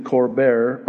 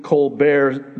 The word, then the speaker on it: Colbert: Recently, Bill Gates, while on The Late Show with Stephen Colbert,